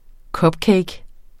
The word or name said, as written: cupcake